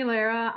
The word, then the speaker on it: Llera: So my name is Jeannie Llera.